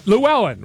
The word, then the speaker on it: Llewellyn: Hey, my last name is Lelewin. So L-L-E-W-E-L-L-Y-N. I have two silent L's. Oh, my goodness. That's like the Trump card. Lelewin? Llewellyn. Llewellyn, right? I guess it would be. Am I right? Yes, Llewellyn.